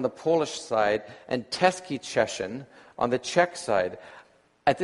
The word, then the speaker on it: Cieszyn: C-I-E-S-Z-Y-N. How do you pronounce it? I heard her say something like Czeszyn into the phone, and that was enough to confirm that I had the right place.